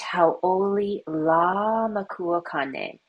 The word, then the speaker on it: Hauʻoli Lā Makua Kane: Let's do our Hawaiian word of the week, which is Happy Father's Day, which is pronounced Hauʻoli Lā Makua Kane. So go ahead and say that with me. We're going to start with Hauʻoli, which means happy. Say Hauʻoli.